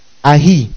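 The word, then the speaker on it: ahi (אַיֵּה): That word where translates the Hebrew word ahi, A-Y, ahi, that's how it's pronounced, which is an interrogative adjective or adverb, that's the word ahi, an interrogative adverb.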